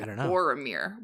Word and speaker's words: Boromir: I was I was totally with you, that I feel like what we were talking about last episode of what Boemir Boromir. Why can I not say his name? Bo- Boromir.